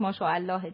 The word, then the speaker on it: ماشاءالله: ماشاءالله البته تلفظ صحیحش ماشاءالله دیگه.